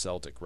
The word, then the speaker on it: Celtic: And I think it should always be pronounced Celtic rather than Celtic. Celtic is like a Germanic pronunciation that doesn't really fit the spirit, in my opinion, of the Celtic. Even if it's more technically accurate, Celtic sounds better than Celtic. The German is more the hard K,